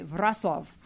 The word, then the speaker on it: Wrocław: I'm going to a place in Poland called WROCLAW, which my computer says Wroclaw. But they would say Wrocław.